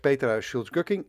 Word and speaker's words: Petra Schulz-Gucking: Zeg ik het zo goed? Ik plaats de umlauts altijd in de wilde weg bij Duitse namen. Maar Petra, heb ik het goed uitgesproken? – je hebt het helemaal super uitgesproken. Daar gaat het vaak mis, maar je hebt het super gedaan.